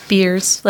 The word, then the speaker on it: Beers: He did say that right. It is beers, like what you drink in a can or bottle.